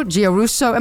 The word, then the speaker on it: Giarrusso: Joe Giarrusso, am I pronouncing your name correctly, first of all? – You are.